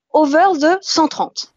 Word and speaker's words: over the 130: C'est comment prononce-t-on le titre de ton blog Alors, on le prononce à la franglaise, hein, à la bonne franglaise. En tout cas, c'est comme ça moi que je le prononce. Donc, je, le dis avec beaucoup de, plaisir et, et d'humour. C'est over the 130.